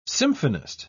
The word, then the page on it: symphonist: British English